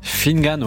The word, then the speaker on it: Finnegan: Est-ce que je prononce bien votre prénom, Finnegan? – Finnegan, ouais. Finnegan, c'est bien. Finnegan à la française, Finnegan.